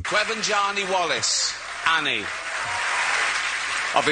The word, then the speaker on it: Quivenzhané: Quivenzhané Wallace, yes.